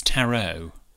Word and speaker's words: tarot: A tarot card? It's tarot. – Oh, is it? – Yeah. The T is silent on the end.